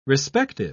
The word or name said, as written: respective